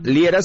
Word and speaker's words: Liera: En mis redes me encuentras por mi nombre, Fernando Liera, se escribe L-I-E-R-A.